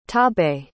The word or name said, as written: torbay